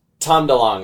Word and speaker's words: DeLonge: Is it Tom DeLonge? – Tom DeLonge is how you pronounce it, and technically he's formerly of being Blink 182.